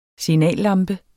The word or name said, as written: SIGNALLAMPE